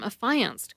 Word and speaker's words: affiance: Peter believes the only way to identify a group of male and female people engaged to be married is to call them affianced couples, or simply the affianced. Affiance, the verb, is pronounced with the emphasis on the middle syllable, affiance.